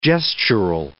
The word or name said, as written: gestural